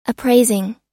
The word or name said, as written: appraising